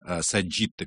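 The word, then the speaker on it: sagitta: Сагитта, конечно, он поет, как полагается, в итальянском произношении латыни, и здесь он абсолютно прав.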